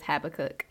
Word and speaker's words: Habakkuk: A lot of people see it and they give it its own its own name, its own pronunciation, but it is Habakkuk.